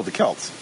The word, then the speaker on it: Celts: Am I pronouncing it wrong? – I don't know. – Celts. – Celt? Oh, the Celts.